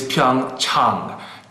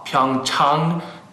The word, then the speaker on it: Pyeongchang: The correct pronunciation is Pyeongchang. Chang, ah, like when you go see your doctor, ah. Pyeongchang, Pyeongchang, Pyeongchang.